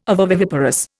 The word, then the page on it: ovoviviparous: Indian